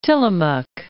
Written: Tillamook Bay

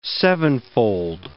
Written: sevenfold

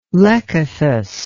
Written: lekythus

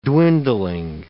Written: dwindling

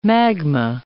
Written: magma

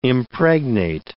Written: impregnate

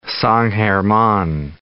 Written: San German

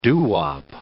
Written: doo-wop

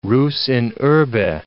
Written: rus in urbe

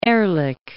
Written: Ehrlich

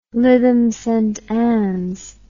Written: Lytham Saint Annes

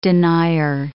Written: denier

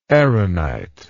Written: aaronite

